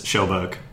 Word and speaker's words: Kjellberg: It's not pronounced Kjellberg. That way. No, it's Schellberg.